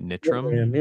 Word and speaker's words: Nitram: Um, while while I have you, um, I, I hope I'm saying the name right. – Nitram. Yeah. yeah.